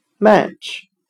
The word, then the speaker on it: match: 我们用谐音法来记忆这个单词 match，它的发音很像麦吃，麦是卖东西的麦，吃表示声音划火柴的声音，呲啦加一声。